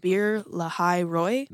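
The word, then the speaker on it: Beer-lahai-roi: Beer Lahai Roy? – That sounds great. – Lahai. – I don't need to take a stab at it at all. That sounds okay. Perfect.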